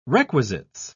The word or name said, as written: requisites